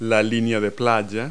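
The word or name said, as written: shoreline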